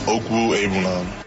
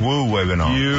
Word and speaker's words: Okwuegbunam: Yeah, it's pronounced Okwuegbunam. – Okwu. – We're not doing this again. – Okwuegbunam.